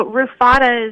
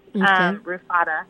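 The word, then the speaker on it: RUFADAA: So RUFADA is usually how I hear it pronounced, RUFADA.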